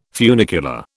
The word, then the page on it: funicular: British English